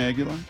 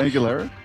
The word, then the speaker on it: Aguilera: Christina Aguilera? – Is it Aguilera? – Yes.